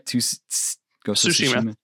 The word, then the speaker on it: Tsushima: Am I saying that right? Tsushima. Okay. – Yeah. – I always butcher that when there's the T in front of the S.